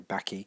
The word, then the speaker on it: Bakke: It was in line, O'Connor's opinion was in line with the ruling in Back, that's spelled B-A-K-K-E, pronounced Back, or some people say Backy,